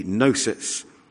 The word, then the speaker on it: gnosis: Well, in the original language of the New Testament, the word for knowledge is gnosis, but with a silent G, gnosis.